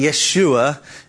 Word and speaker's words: Yeshua: It's really just a transliteration of sounds like the original word of the Hebrew word, which we would pronounce now Joshua, more correctly Yeshua, if you're speaking it in a more Hebrew kind of way.